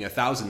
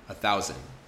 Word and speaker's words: thousand: So the first thing is I don't pronounce the D. A thousand. See, I don't have a d there or a thousand. I just say a thousand as if it was spelled with just an n at the end.